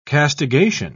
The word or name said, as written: castigation